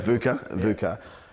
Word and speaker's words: VUCA: You mentioned VUCA. Am I saying it right? – Yeah, VUCA.